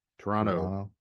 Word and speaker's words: Toronto: which I also learned that people who live in Toronto don't pronounce that second T. It's not as well defined. Toronto.